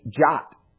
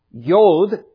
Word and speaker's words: Yod: Verse 73 is Yod, or to pronounce it our way, it would be Jot. When the Lord talked about a jot and tittle, the jot or jot, jod, Yod is actually how they would pronounce it in the Hebrew, is actually a Hebrew letter.